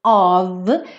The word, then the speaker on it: of: Mas quem acompanha a série Aprenda Inglês com Música desde o começo já ouviu 500 vezes que of é sempre pronunciado como um V, tá?